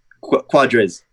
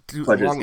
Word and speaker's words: Quadrez: Another guy that perhaps the offense can rely on is running back, and I'm going to butcher this, Quadrez Wadley. How'd I do? – Quadrez. – Quadrez. Ah. – Quadrez. Yeah, kind of like the leg part. You just say the quad, and then you just kind of go up in there.